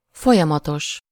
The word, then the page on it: folyamatos: /ˈfojɒmɒtoʃ/